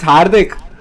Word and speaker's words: Hardik: It's Hardik.